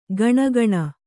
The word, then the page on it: ಗಣಗಣ: gaṇa gaṇa